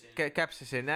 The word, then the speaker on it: capsaicin: But you also talked about capricin and cheese. – Capsaicin. – I finally kind of pronounced it right.